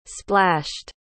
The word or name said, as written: splashed